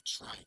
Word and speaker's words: try: Ok, se escribe como tree, pero se dice try, try, ok. – Try. – Try, muy bien. Como hay dos, hay tres, hay cuatro, pues con una tr antes.